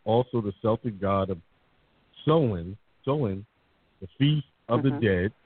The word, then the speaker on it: Samhain: Samhain, S-A-M-H-A-I-N. The pronunciation is Samhain.